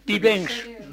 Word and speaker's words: Bibens: Bibench. B-I-B-E-N-S. – Ah oui. – Bibench, la prononciation, c'est Bibench.